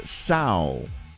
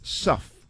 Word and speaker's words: sough: Word is sow. Sow. Or could be pronounced suff. S-O-U-G-H.